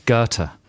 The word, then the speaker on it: Goethe: G-O-E-T-H-E. – Goethe.